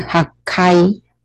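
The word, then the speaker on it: 八階: 那再来呢，就是八楼呢，它有两个读音 hachikai 或者是 hakai，两个都可以，hachikai 或者是 hakai，两个都可以。所以那右边这个 hakai 会比较常用，因为呢，它的读音比较少。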